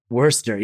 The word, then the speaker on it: Worcester: Worcester, yes. And it's spelled yeah. weirdly. Like the Worcester sauce, I guess.